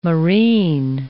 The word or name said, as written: moreen